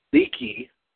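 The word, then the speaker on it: Leakee: Was it A, Joe Inouye, B, the Samoan Destroyer, 3, Leaky, that's how you pronounce it, or D, he was always Roman Reigns? – Leaky.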